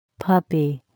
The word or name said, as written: puppy